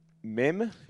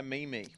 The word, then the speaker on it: meme: I saw a good mem. How do you pronounce that? – I like to call it a meme. – Let's call it a meme. – A meme is much better.